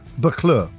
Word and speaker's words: Buccleuch: Buccleuch, yeah. – Okay. – It's spelt... B-U-C-C-L-E-U-C-H. – Okay. – But it's pronounced Buccleuch.